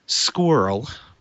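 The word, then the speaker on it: SQRL: He coined the term spyware, created the first anti-spyware program, plus SpinRite, Shields Up, and Squirrel, which I like saying. That's S-Q-R-L, if you don't like acronyms, or acronyms pronounced as words.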